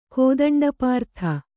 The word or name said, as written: ಕೋದಂಡಪಾರ್ಥ